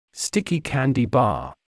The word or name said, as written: sticky candy bar